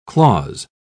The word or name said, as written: clause